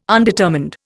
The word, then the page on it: undetermined: Indian